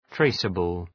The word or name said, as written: traceable